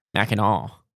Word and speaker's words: Mackinac: Mackinac is how you pronounce it.